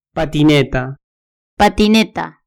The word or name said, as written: patineta